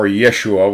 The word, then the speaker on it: Yeshua: Now, Joshua, or Yeshua, which is the Hebrew pronunciation, is the same word as Jesus in English.